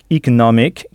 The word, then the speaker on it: economic: Donc, the economic situation, avec economic qui s'écrit bien avec un e au début, mais ce e se prononce i. Pensez au mot email qui s'écrit lui aussi avec un e initial prononcé i.